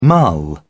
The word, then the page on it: mull: British English